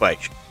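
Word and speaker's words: Beishir: And Clint Beischer is, you just type in my name on social medias. – How do you spell that, sir? – C-L-I-N-T-B, as in boy, E-I-S-H-I-R. Clint Beischer.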